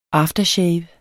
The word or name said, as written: aftershave